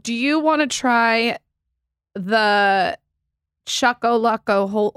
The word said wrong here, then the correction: cuckolding: Wait, you mean cuckolding?